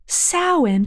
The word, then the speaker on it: Samhain: It's pronounced Samhain, but the real Ghostbusters cartoon called this creature Samhain.